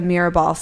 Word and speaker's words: Mirabal: You tell this story about the Mirabal sisters.